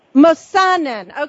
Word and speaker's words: Mossanen: Mossanen. Okay, I put the accent on the wrong syllable.